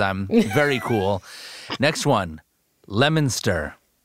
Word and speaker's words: Leominster: Leominster is a town. – We've saved face, Helen. – Yes, it's spelled L-E-O-M, but it's pronounced like the fruit lemon.